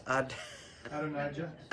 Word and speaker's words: Adonijah: Adonijah. – Adonijah, thank you.